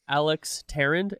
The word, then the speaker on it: Tarrand: Today, it is my pleasure to welcome my new friend, Alex Tarrand.